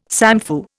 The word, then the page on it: samfu: Indian